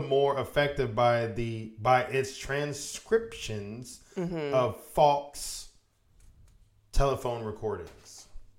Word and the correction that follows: faux: Did I say that right? – Faux. Faux. – Faux. That's stupid. – It's French.